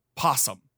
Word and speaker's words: opossum: And I don't know if I just was like just canceling it out or whatever, but you apparently like the word is spelled O-P-O-S-S-U-M, but down here where Wes Anderson is from, that word is pronounced possum.